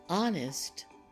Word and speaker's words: honest: Column 3 also has the silent H at the beginning. Let's say these words. Air, herb, and sometimes people will say herbs, but most of the time the word is pronounced herb. Homage, honest, honor, and hour. They begin with H, but the H is silent.